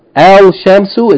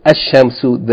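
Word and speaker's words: Ash-Shamsu (الشمس): For example, Al-Shamsu is actually pronounced Ash-Shamsu. The L, Shams, the L is yani assimilated into the first letter, the sun letter, Sheen, and therefore instead of saying Al-Shamsu, you say Ash-Shamsu.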